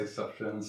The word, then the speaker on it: Sufjan: Sufjan Stevens. – I'm a fan of Sufjan. I say Sufjan Stevens as well.